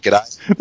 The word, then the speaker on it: G'day: And just so the audience understands what we're talking about, you know, when y'all in Australia greet people, you say G'day. – Yeah. – Did I pronounce that right? G'day. – That'll do.